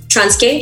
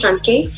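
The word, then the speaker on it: Transkei: And the other one was for Beauty, when she's really pressed on her way to Soweto from Trans-K, that's how it's pronounced, Trans-K,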